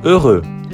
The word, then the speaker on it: heureux: Yeux, os olhos, joyeux, alegre, heureux, feliz. Nessas três palavras, a dificuldade da pronúncia é devida ao som E, que em francês é igual à pronúncia da letra E do alfabeto. E.